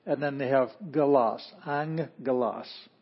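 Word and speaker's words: angelos (ἄγγελος): the first gamma has a ng sound, ng, ng. And then the second gamma actually does still have the g sound, which is not like j, it's not like jif, it's g, as in girl. It's a hard G. So, in this case, this is angelos.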